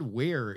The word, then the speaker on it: Weiher: Also, Ted Ware is pronounced W-E-I-H-E-R. – Is it pronounced or spelled? Because you said it's pronounced and then spelled it out. – Sounds like Ware, yeah. – All right, Ted Ware. – But he's like, it's pronounced W-E-I.